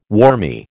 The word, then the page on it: wormy: American English